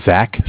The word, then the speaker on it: Zach: So my name is Zach, spelled Z-A-C-H.